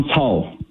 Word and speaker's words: Cao: Is it Pastor Cao? – Yeah, Pastor John Cao. – Thank you. Can you tell me his background? What's the story? – Thank you, for having me once again. What happened to Pastor John Cao, his last name is spelled as C-A-O, C for Charlie, C-A-O.